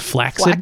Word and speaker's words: flaccid: Do you know the correct pronunciation of the word flaccid is flaccid? – Flaccid.